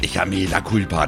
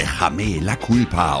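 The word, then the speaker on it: Échame la culpa: Das heißt nicht Echame la culpa, das heißt Echame la culpa.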